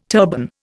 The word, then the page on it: turban: Indian